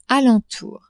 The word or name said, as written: alentour